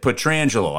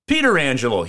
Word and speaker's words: Pietrangelo: And I said, okay, how do you say it? And he said, Pietrangelo. I said, that's what your son says. I go, how is it that your brother says Pietrangelo? He goes, because he's been saying it wrong his whole life, and we give him grief about it at every family function.